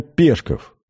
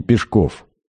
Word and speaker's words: Пешков: Именно Пешков, а не Пешков. Сам Горький в повести детства ставит ударение на последнем слоге.